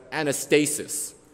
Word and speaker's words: Anastasis: There's the Jesus, who's a male deity, and then there's the resurrection, which in Greek is pronounced as Anastasis, in reference to a female deity.